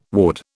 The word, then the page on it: ward: British English